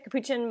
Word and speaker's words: capuchin: capuchin. Is that how you say it? Capuchin monkey? – Capuchin.